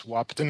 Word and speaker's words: Wahpeton: a facility in Wahpeton. Am I saying that right? – Yes, Wahpeton, North Dakota.